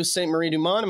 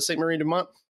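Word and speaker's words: Sainte-Marie-du-Mont: Played a little bit of the new Saint Marie Dumont. Am I saying that right? It was the Saint Marie Dumont map.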